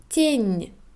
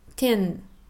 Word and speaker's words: тень: Schatten, тень, nicht ten.